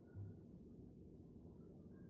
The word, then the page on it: kaelketh: kashmiri